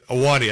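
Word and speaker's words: Ahwahnee: We stayed at the Wauna Inn, the famous old one that they use some of the interior. Awani? Is it the Awani? – Awani. I couldn't pronounce it correctly there. Awani Inn.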